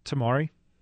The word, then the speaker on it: Tamari: Tamari. – Tamari. Am I saying it right? Tamari. – Yeah. – When you say it's like a little mur, Tamari, it's a little bit different.